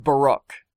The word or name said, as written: Baruch